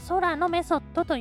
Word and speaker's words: 天体のメソッド: えー、でねもう一つは、えー、天体のメソッドドと書いて、あの、え空のメソッドと読むらしいです。